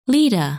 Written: Leda

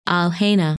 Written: Alhena

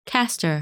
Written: Castor

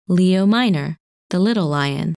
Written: Leo Minor

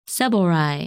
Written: Cebalrai

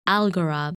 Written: Algorab

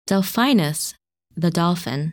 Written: Delphinus

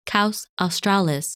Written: Kaus Australis

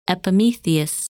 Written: Epimetheus

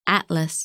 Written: Atlas

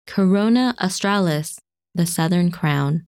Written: Corona Australis

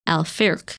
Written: Alfirk

